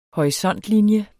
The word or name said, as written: horisontlinie